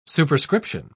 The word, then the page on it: superscription: British English